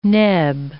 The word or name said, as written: neb